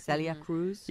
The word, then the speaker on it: Celia Cruz: Celia Cruz.